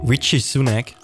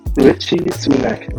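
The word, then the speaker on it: Rishi Sunak: Fall äh, Richie Sunak, so wird der Name ausgesprochen. – Richie Sunak.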